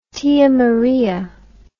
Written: tia maria

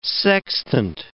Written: sextant

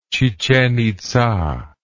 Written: chichen itza

